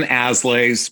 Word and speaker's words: Aslay: My name is Jonathan Aslay, last name is spelled A-S-L-A-Y, A-S-L-A-Y.